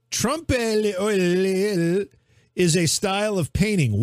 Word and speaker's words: trompe l'oeil: It's T-R-O-M-P-E, trompe. And it's l'oeil, which is L apostrophe O-E-I-L. L apostrophe O-E-I-L. Trompe l'oeil.